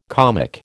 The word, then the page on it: comic: American English